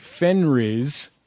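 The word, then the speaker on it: Fenriz: It focuses on two musicians, one called, and I'm going to mispronounce it, Fenris. – Perfect.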